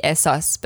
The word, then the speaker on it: ehsas: Like in English, it would probably be ehsas, but ehsas. – Okay. You really pronounce the H.